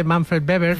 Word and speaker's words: Manfred Weber: Y está Manfred Weber, que se dice Weber, no se dice Weber, ¿vale? Sí, sí. Se dice Manfred Weber.